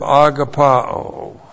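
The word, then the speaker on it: Agapao (ἀγαπάω): And then the verb agapao. So we've had the noun agape. Here's, it comes from the verb agapao. A-G-A-P-A long O. Agapao.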